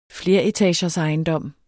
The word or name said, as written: fleretagersejendom